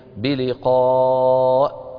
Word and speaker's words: بلقاء: بلقاء كما ترون في المصاحف، انظروا إليها، الهمزة يعني الياء المكتوبة، هذه الياء كرسي للهمزة، فهي تكتب ولا تنطق الياء. من الذي ينطق؟ الهمزة. انتبهنا؟ نحن في الإملاء الحديث نكتبها على السطر، ليس كذلك؟ إيه؟ فإذا هذه لو وقفنا عليها شو بنقول؟ بلقاء. ولو وصلناها بلقاء ربهم، فالياء هذه ما لها نطق لا في الوصل ولا في الوقف.